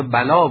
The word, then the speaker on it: بلی: این بلی را که همان در واقع بلا باید گفت کوتاه‌تر تلفظ کنیم.